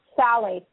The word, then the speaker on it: phthalates: It's spelled P-H-T-H-A-L-A-T-E-S, and it's pronounced phthalates, like T-H-A-L-L.